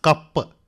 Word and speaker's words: കപ്പ്: ഈ ലോക കയ്പ് മത്സരം തുടങ്ങിയതോടെ എന്റെ സമയബോധം ആകെ തകരാറിലായിരിക്കണം ഏട്ടാ. – എടാ, ലോക കയ്പ് മത്സരമല്ല, ലോക കപ്പ്. ഇടയ്ക്കൊക്കെ ഒന്ന് നാക്ക് വടിക്കണം, അല്ലെങ്കിൽ ഉച്ചാരണ ശുദ്ധി കിട്ടില്ല.